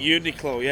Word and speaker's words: Uniqlo: I just saw Federer's coach wearing a Barilla hat and some Uniqlo. – Is that how you pronounce it? Uniqlo. – Uniqlo, yeah.